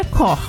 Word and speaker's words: Koch: Se escreve Koch, K-O-C-H, como vemos em uma rede de supermercados de Santa Catarina. Mas popularmente as pessoas falam Koch, e na verdade é Koch. Como no sobrenome, no português, falado com um O aberto, como se fosse acentuado e, quem sabe, dois H seguidos juntos bem pronunciados, com os lábios abertos em formato de O.